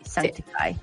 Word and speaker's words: Sanctify: No, sí, ya viene, ya viene Don son las 10 con 4, nos vamos a ir a escuchar Years and Years con Sanctify. ¿Cómo se dice esto? Sanctify. Sanctify. Sanctify. ¿Cierto? Sí, Sanctify. Eso, con Years and Years.